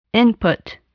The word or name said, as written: input